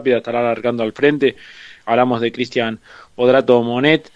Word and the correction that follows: Mioneto: lo digo bien, ¿no? Mioneto, perdón. Mioneto, sí.